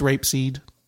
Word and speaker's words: rapeseed: Is that's how it's pronounced, right? – Yes. – I'm not off base. Okay. Yeah. I don't know what that is. It's not grapeseed? – Nope. It's rapeseed.